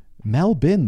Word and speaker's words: Melbourne: in the pronunciation guideline, a pronunciation guide for Melbourne. Melbourne, though. M-E-L-B-I-N.